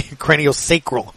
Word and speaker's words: craniosacral: They have your regular massage and treatments, shiatsu massage, athlete and weekend warrior specific massage, a cranial scroll therapy massage. Did I? Sacral Okay, I mispronounced that. Thank you, Dr. Josh. You literally just walked out enough to tell me craniosacral.